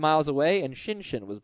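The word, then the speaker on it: Schoenchen: We pronounce it Shinshin. – Yeah, I've learned. – I've also heard Shoenshin, but these people are wrong.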